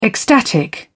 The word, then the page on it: ecstatic: British English